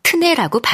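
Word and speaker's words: tenez: 당시에는 마지막 Z를 발음해 테네즈였지만 이후 이 발음이 탈락하고 태가 트로 약화되면서 현대 프랑스어에서는 트네라고 발음한다.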